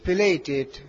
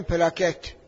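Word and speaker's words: platelet: حالا تلفظ دقیقش پلیتت، حالا کار نداریم، ولی مردم میگن پلاکت، حتی دکترها میگن پلاکت، ولی تعبیر ریزش پلاتت یا پلیتت، حالا کار نداریم.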